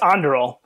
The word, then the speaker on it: Anduril: It's Anduril.